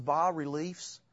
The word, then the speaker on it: bas-reliefs: I believe the word is pronounced, bas-reliefs,